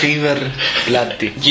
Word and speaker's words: River Plate: How's it pronounced? River Plate, right? – Yes, I think that's it. – River Plate.